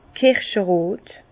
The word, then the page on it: kirscherot: Bas-Rhin; Herrlisheim